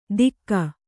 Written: ತಿಕ್ಕ

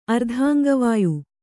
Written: ಅರ್ಧಾಂಗವಾಯು

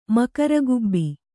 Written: ಮಕರಗುಬ್ಬಿ

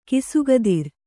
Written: ಖಾಸಕುದುರೆ

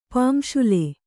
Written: ಪುಂಶಿಲೆ

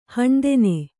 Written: ಹೀನತನ

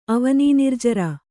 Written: ಅವನೀನಿರ್ಜರ

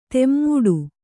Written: ತಮ್ಮಟ

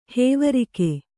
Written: ಹೇವರಿಕೆ

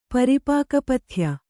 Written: ಪರಿಪಾಕಪಥ್ಯ

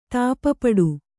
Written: ತಾಪಪಡು

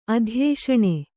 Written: ಅಧ್ಯೇಷಣೆ

